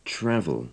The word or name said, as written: travel